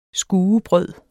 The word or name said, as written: skuebrød